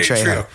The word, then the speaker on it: Trejo: Did I say his name wrong? – You said Drio. I was Drio. Like, who is this guy? Yeah. Danny Trejo.